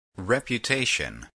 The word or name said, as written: reputation